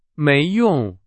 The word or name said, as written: 没用